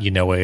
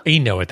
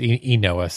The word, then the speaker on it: Enoa: And actually, we found out this week that it's not Yanoa, it's something else. Like, I'll pull up the pronunciation. – I think it's Enoa. – Enoa, thank you. Yeah, it's Enoa.